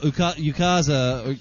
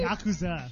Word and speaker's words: yakuza: You know, Yukaza... How's it pronounced? Yakuza? – Look at he's like, why are you looking at me? Because like I'm Asian? – Yakuza.